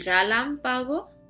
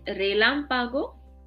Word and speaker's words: relámpago: El relámpago. Sorry, it's el relámpago, lightning.